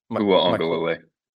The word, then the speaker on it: Uiagalelei: So Mateo Uiagalelei, how do you say it? – Uiagalelei. – Uiagalelei.